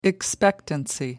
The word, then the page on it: expectancy: American English